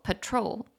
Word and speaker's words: patrol: 这一集我有一个字发音发错了，就是巡逻这个字，我念成 patrol，但是它真正的发音应该是中音在后面，念作 patrol，巡逻 patrol。